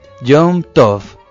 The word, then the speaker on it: Yom Tov: Yom Tov, fiesta o festividad en hebreo, en pronunciación yiddish.